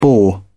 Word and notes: bore: British English

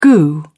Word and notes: goo: British English

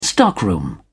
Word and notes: stockroom: British English

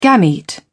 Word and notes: gamete: British English

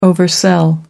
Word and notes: oversell: American English

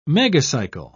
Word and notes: megacycle: British English

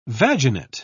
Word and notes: vaginate: British English

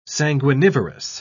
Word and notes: sanguinivorous: British English

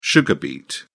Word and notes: sugar beet: British English

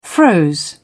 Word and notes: froze: British English

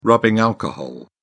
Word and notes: rubbing alcohol: British English

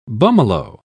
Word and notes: bummalo: British English

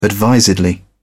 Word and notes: advisedly: British English

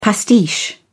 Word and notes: pastiche: British English

